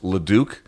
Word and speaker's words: Leduc: King, I think it's pronounced Leduc, L-U-D-U-C, L-E, excuse me, L-E-D-U-C.